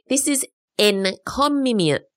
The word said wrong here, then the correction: encomium: Oh, I'm not saying that right. Encomium. Encomium. That's E-N-C-O-M-I-U-M. Encomium. It sounds like a weird version of the economy, but it's not.